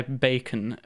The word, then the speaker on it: Bekon: And there's the guy Bacon, which is spelt B-E-K-O-N, has an album that he released in 2018 quite quietly on his own, like independently, called Get With The Times.